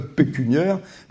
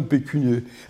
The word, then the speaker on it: pécuniaire: pécunier, ça n'existe pas, puisque ça s'écrit AIRE, c'est et masculin et féminin. Donc j'ai un problème pécuniaire, mais pas un problème pécunier.